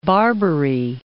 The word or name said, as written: Barbary Coast